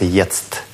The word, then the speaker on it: jetzt: На всякий случай, там два Т, произносится jetzt.